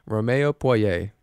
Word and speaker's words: Romeo Poirier: Goodness, I've been having a pronunciation issues today. song by Romeo Poirier. Wow. Romeo Poirier. There it is.